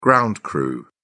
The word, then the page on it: ground crew: British English